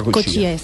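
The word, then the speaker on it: Cotia: Se escribe Cotilla, pero se pronuncia Cochía.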